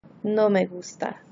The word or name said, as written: no me gusta